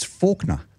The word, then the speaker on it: Faulkner: I work with a bloke called, it was written Faulkner, but if you pronounce it that way, he'd rip your throat out. It's Faulkner. So when you see Faulkner written down, you've got to pronounce it Faulkner.